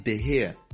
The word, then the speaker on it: De Gea: Then the Manchester United goalkeeper, it's David De Gea. David De Gea, not David De Gea, not David De Gea. No, it's David De Gea. The G is silent.